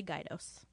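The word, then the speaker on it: Gaydos: It's actually Gaidos. – Gaidos. Mm-hmm. Thank you. – It's spelled G-A-Y, but it's pronounced Gaidos.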